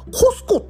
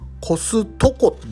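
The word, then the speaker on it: Costco: 正式はさ、コスコっていうんだってね、あれ。が向こうの人の発音だと、コスココスコっていう感じで。 t を発音しないんだよね。コストコじゃなくて、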